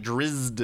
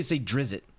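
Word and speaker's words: Drizzt: It's just like, I get Drist, but she's like Drizzed. And I'm like, that's just weird sounding to me. – Most people say Drizzit.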